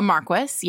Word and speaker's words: Marquess: A Marquess, yes.